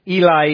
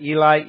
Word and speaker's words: Eli: "And about the ninth hour, Jesus cried out with a loud voice, saying, 'Eli, Eli,'" I'm using a Western pronunciation there because it sounds a lot like Elijah. "'Eli, Eli, lema sabachthani,' that is, 'My God, why have you forsaken me?'